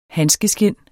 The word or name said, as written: handskeskind